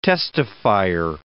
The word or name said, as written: testifier